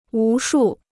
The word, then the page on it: 无数: wú shù